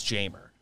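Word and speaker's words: Jeimer: I was scared to do him because I wasn't sure how to pronounce his first name, but that is how Baseball yes. Reference said to pronounce Jamer. – Yeah. – So I think you do hit the J.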